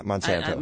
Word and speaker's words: Monsanto: Is that how you say it? Monsanto? – Yeah. Why? Yeah, Monsanto.